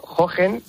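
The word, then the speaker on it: Hohen: Bueno, Hogen, aunque se pronuncie con J, va con dos H's y hace referencia a Alfonso de Hohenlohe,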